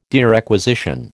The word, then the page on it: derequisition: American English